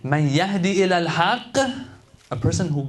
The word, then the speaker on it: يَهِدِّي: أَفَمَنْ يَهْدِي إِلَى الْحَقِّ أَحَقُّ أَنْ يُتَّبَعْ أَمَّنْ لَا يَهِدِّي not يَهْدِي يَهِدِّي إِلَّا أَنْ يُهْدَى.